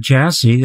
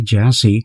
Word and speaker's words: Gyasi: Before that, we heard Jassy, that's spelled G-Y-A-S-I, Jassy, who comes to the blues from a most interesting background.